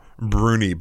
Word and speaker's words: brownie: They pronounce it closer to Bruni, but I'm not going to do that because it would sound silly coming from me.